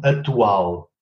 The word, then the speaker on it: atual: Zum Beispiel das Wort äh, aktuell, ja, wird in Portugal ausgesprochen atual. Ja. Geschrieben wurde es aber aktual. Aber da es ein stummer Konsonant ist, verschwindet das C.